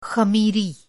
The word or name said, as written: ख़मीरी